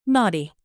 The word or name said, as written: naughty